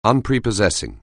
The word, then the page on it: unprepossessing: anglais